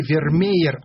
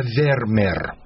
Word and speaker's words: Вермер: Так вот, для меня все это по-голландски, но в одном вполне авторитетном издании я прочитал, что на письме 2 русских «е» читается как одно «е». То есть, не Вермеер, а Вермер.